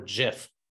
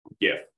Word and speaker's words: GIF: Do you pronounce it GIF or JIF? – GIF. – Okay, GIF. Good. That's the correct answer.